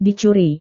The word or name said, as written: dicuri